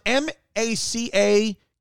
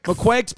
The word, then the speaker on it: macaque: McQuakes pronunciation. How do we... Let's hear it. – Macaws. – Macaque. – It sounds like it's saying macaque. – Macaque.